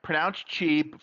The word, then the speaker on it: Qi: The MagSafe's charging specification, okay, allows for 15 watts of power to be transmitted using the Qi, and that's pronounced Qi from the original Chinese, but written as QI, wireless standard created by the Wireless Power Consortium back in 2008.